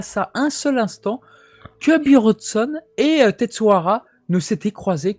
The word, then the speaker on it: Buronson: quand on dit euh, Bronson en japonais, en fait, pour le prononcer, on dit Bronson. Et du coup, Buronson, euh, tout simplement.